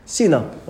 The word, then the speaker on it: cena: Cena, okay. Because we don't pronounce it with our English, with a curly K. We do it with an S, okay.